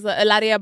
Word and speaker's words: Hilaria: That's wild to me because, the last time you were here, it was Hilaria Baldwin. – Thank you for saying it correctly.